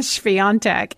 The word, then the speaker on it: Świątek: And the number one seed, let me pronounce her name correctly, Iga Świątek from Poland.